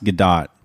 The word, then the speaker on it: Gadot: I think it's actually pronounced Gadot. – Oh, damn. – Which is a little counterintuitive.